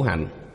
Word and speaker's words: hạnh: Vô lượng kiếp trung tu khổ hạnh. Chữ hành này vẽ một vòng tròn, dùng làm động từ, đọc là hạnh, khổ hạnh.